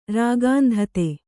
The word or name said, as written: ರಾಗಾಂಧತೆ